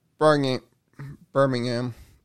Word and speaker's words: Birmingham: And then in Birmingham. There's still 61. The H is silent there in England on this city.